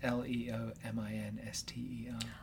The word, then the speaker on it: Leominster: The town I grew up, the town next to my hometown in Kentucky, is spelled V-E-R-S-A-I-L-L-E-S. – Okay. – It is pronounced Versailles. – Of course it is. Mind you, I live near Leominster. Which is Leominster. – Oh, yeah, Leominster is the rest of us would guess.